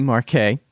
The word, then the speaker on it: Marquet: And my guest is Anthony Marquet. Did I say it right? – You did. – Good. And like Parquet.